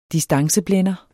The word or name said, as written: distanceblænder